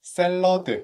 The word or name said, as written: cooking together